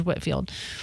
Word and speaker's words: Whitefield: I knew his name was George Whitfield, but it's spelled like it's Whitefield.